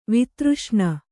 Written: ವೇತ್ರಾಸನ

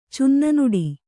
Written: ಚುನ್ನನುಡಿ

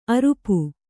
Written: ಅರಿಪೂ

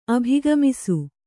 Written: ಅಭಿಗಮಿಸು

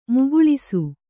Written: ಮುಕ್ಕುಳಿಸು

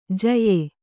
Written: ಜ್ಯಾ

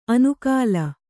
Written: ಅನುಕಾಲ

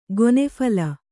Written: ಗೊನೆಫಲ

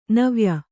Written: ನವ್ಯ

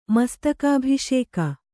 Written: ಮಸ್ತಕಾಭಿಷೇಕ

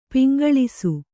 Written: ಪೊಂಗೆಲಸ

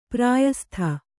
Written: ಪ್ರಯಸ್ತ

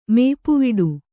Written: ಮೇಪುವಿಡು

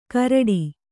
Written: ಕೂರಿಟ್ಟಿ

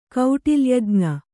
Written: ಕೌಟಿಲ್ಯಜ್ಞ

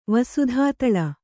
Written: ವಸುಧಾತಳ

